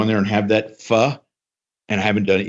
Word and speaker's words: pho: So I have mentioned before on the show, and I'm going to go to you, Barry, to give me the proper pronunciation, pho. – Pho. – Okay. Pho. Yeah, it's not pho, it's pho. That's why right.